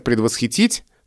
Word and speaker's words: предвосхитить: И хочется сказать «предвосхитить», так же, как мы говорим «восхитить». На самом деле это ударение в норме держится, каким оно было когда-то раньше.